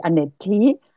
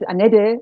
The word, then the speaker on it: Anette: wir haben so einen Dialekt hier und da wird mein Name ganz oft so als Annette ausgesprochen und deswegen habe ich ihn elegant abgekürzt als Annette.